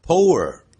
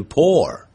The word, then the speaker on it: pour: 啊，这个倒东西倾倒这个不要念成呢 pour，实际上是念成 pour， pour。